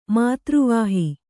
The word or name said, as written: ಮಾತೃವಾಹಿ